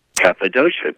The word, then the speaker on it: Cappadocia: You have a daughter now who you play with often, Bethany, and also Rufus, I'll probably pronounce this wrong, Cappadocia. – Cappadocia, exactly.